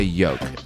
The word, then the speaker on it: yolk: I say like egg yolk. I say yolk. I like drop the L.